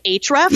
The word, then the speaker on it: Ahrefs: I'm going to botch the name because it's spelled Ahrefs. – Oh, gotcha. – But I think it's pronounced Ahrefs.